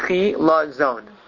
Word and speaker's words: chilazon: Pronounce that again, chila zon. – Chila zon.